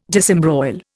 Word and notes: disembroil: Indian

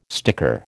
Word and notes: sticker: American English

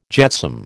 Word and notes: jetsam: American English

